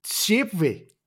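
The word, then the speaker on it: Tshiebwe: Vi og så hedder han T-S-H-I-E-B-W-E. E e. Chipwe. Chipwe.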